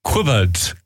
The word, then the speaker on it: cwpwrdd: It says difficult to pronounce. It says here, cupboard, cupboard. Cupboard. It's pronounced like cupboard.